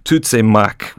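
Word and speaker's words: Tuzemák: So it's Tutsimak, with a kind of long vowel at the end there.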